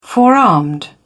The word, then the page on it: forearmed: British English